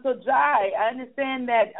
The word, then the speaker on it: Jai: Is it J-A-I? – Yeah, it's Jai. It's pronounced Jai.